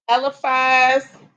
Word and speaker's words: Eliphaz: Eliphaz.